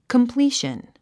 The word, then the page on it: completion: kəmplíːʃən; コンプリーション